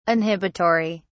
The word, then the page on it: inhibitory: British English